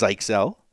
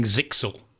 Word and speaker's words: Zyxel: and then finally, Zyxel, which we found out it's pronounced Zyxel. Hard coded admin password found. Patch now. – I must say, for 30 years, I've been saying Zixel, mm-hmm. and everyone I know has called it Zixel.